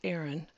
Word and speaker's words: Ayron: They said that history helped them this season, but they attribute a lot of their success to first-year coach Aaron, pronounced Aaron, it's spelled A-Y-R-O-N, Worthington, who set four goals at the beginning of the year: be undefeated, win regionals, make it to state, and win state.